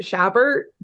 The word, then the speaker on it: Chabert: Is it that she's voiced by Lacey, how do you say her last name? Chabert.